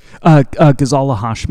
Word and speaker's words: Ghazala Hashmi: Ghazala Hashmi.